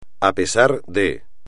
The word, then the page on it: a pesar de: espagnol